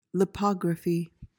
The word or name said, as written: lipography